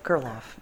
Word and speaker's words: Gerloff: Is that how you say your last name? – Yep, Gerloff. Mm-hmm.